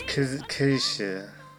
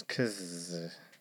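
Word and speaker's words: Kiesza: No. It's Kesha. It's Kesha.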